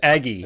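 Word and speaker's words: AG5: AG five? God, or maybe yeah. it's pronounced Ag five. Yeah, it's Aggie.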